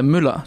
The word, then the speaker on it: Müller: Äh, Müller tatsächlich. Ah. – Ja, aber das kann natürlich niemand aussprechen im Ausland.